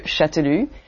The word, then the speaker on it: Chastellux: And his uncle is called François Jean de Châtelux. You write that C-H-A-S-T-E-L-L-U-X, Chastelux. It's a complicated name to pronounce. – Châtelux is a pronunciation, okay.